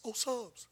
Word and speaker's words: Psalms: Now, it looks like Psalms, but the P is silent. He's like, huh? Psalms.